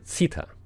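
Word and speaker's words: Zither: 那个乐器叫奇特琴，今年中央电视台在直播的时候把它翻译成奇特琴，其实我们很多人以前更习惯于翻译成奇特尔琴，这德文的发音是 Zita，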